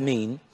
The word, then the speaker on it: min: The Hebrew, I quote, the Hebrew word, and here he spelled it M-I-N, but it's pronounced mean, as species.